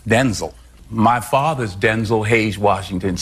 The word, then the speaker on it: Denzel: Mom said you're Denzel, but it's supposed to be pronounced Denzel. – Like D-E-N-Z-I-L, Denzel.